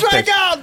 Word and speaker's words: PVRIS: Isn't that how it's spelled like that? – P-V-R-I-S. Yes, yeah. Sir.